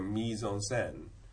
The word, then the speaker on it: mise en scène: If you look at mise en scène, you can see that the French people have retained the E pronunciation of the I letter and the A pronunciation of the E letter. We might keep E in mise because we've got the French word, but we also have an English word for scène, which is scene.